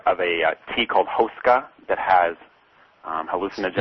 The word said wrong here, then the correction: ayahuasca: It's pronounced ayahuasca, actually. – Oh, how do you say it? – Ayahuasca. – Ayahuasca, okay.